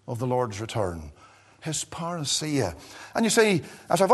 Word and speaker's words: parousia: the word "parousia," and here it is again in verse number 15, "we which are alive and remain unto the parousia."